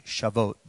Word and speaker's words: Shavuot: S-A-V-O-U-T. S-A-V-O-U-T. Shavuot. That's how it's pronounced.